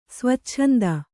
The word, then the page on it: ಸ್ವಚ್ಛಂದ: svacchanda